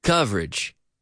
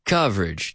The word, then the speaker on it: coverage: Coverage. Coverage. Two syllables.